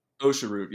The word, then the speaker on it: osha root: I think the first one on my list here is OSHA root. Did I say that correctly? – OSHA root, yep.